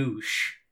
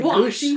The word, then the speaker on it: gouache: That word is in fact pronounced a gush. – Yeah. – Yes, not okay. Goosh. – Like you're goosh. – Like you tried to make it sound. – Like gouache. Gouache.